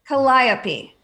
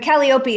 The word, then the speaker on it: Calliope: Calliope. But Calliope is how you pronounce it if you're Greek.